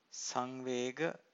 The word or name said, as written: sanvega